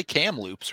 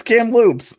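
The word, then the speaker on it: Kamloops: You just say Cam Loops, right? – That's what I'm saying. It's Cam Loops.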